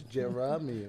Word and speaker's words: Jeremy: Somehow the A is silent and I don't even have it in my name. It's Jeremy. Like, what is that?